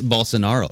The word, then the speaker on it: Bolsonaro: Bolsonaro.